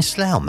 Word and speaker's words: Slough: She says, I'm in Slough now. It rhymes, doesn't it, Rebecca?